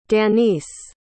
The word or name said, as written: danice